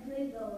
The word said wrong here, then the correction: beluga: How do you say it? – Beluga whale. – Beluga whale.